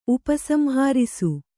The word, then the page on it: ಉಪಸಂಹರಿಸು: Kannada; upa samharisu